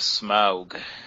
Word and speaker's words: Smaug: The Desolation of Smaug. – Thank you for that correct pronunciation.